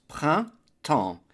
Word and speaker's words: printemps: The word for spring is le printemps, two syllables, printemps. The P-R-I-N is a nasal I-N as in invité, print, first syllable. Second syllable is temps, which is the word for time, and the E-M-P-S is a nasal sound, sounding like en as in enfant, child, so we get printemps, spring, or springtime.